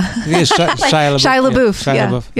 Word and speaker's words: Shia LaBeouf: You know, I always thought I didn't have any in mind at the time, but like afterward, like Shia LaBeouf, I think, if I'm pronouncing. – Oh, yeah. Yeah. Shia LaBeouf.